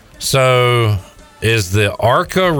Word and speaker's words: ARCA: You said there was an ARCA race scheduled.